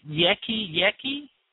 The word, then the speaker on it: Yeki Yeki: The next song I want to play, and help me with pronunciation here, is it Yeki Yeki? – Perfect. Perfect. you did well. That's it.